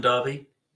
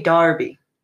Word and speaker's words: Derby: So you say Derby. – Derby. – That is weird. Okay, moving on. That is very strange, though. That's why I'm always getting confused, because it's spelled Derby, but you guys say Derby.